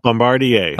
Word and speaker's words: Bombardier: But first, Bombardier or Bombardier. How do you say it? How do you do it? – Bombardier. – Okay, we'll go with that.